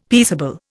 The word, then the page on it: peaceable: Indian